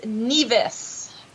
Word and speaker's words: Nevis: It's Nevis.